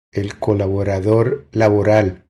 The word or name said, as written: El colaborador laboral